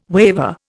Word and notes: waver: Indian

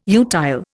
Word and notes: utile: Indian

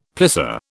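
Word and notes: plissé: British English

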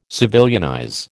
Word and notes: civilianize: American English

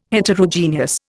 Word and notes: Heterogeneous: Indian

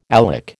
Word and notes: alec: American English

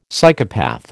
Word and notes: Psychopath: American English

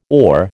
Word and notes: ore: American English

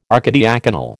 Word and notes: archidiaconal: American English

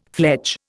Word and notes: fledge: Indian

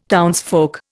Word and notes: townsfolk: Indian

